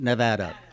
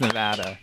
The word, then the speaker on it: Nevada: Okay, it's pronounced Nevada. – It is pronounced Nevada.